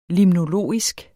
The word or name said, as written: limnologisk